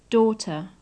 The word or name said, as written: daughter